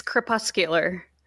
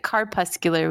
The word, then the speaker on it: crepuscular: It's crepuscular. – That's what I was gonna say. – You should have trust your gut. – But I was gonna pronounce it carpuscular, which is not right.